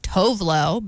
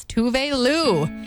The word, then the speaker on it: Tove Lo: That's Habits, my Mix FM, otherwise known as Tovlo, but I just heard her pronounce her name for the first time the other day, and it's Tove Lo.